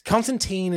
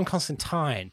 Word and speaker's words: Constantine: Constantine is the very American pronunciation. And I think the movie Constantine really sealed that for a lot of people. – Yeah. – But it is John Constantine.